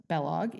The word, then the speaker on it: Balogh: It's pronounced Bellog, even though it's spelled B-A-L-O-G-H.